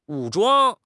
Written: 武装

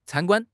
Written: can1 guan1

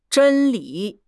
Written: 真理

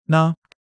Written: 呢

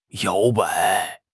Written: 摇摆

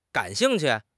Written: gan3 xing4 qu4

